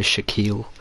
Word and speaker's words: Shakeel: Did I really just die to Shaquille? Okay. – Shaquille. – Yeah. Not even spelt like Shaquille O'Neal. – Bro, who gives birth to a baby and says, yeah, I'm gonna call that guy Shaquille? – And who decides to spell it? S-H-A-K-E-E-L? – Shaquille.